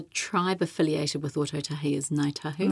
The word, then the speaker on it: Ngāi Tahu: Ngai, well, that you've got that NG sound at the beginning, yes. Ngai tahu. The kai tahu dialect is an interesting one because the kai tahu dialect replaces NG with a K.